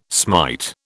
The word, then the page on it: smite: British English